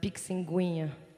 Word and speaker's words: Pixinguinha: If you don't read Portuguese, it's spelled, you're going to pronounce it as Pixinguinha.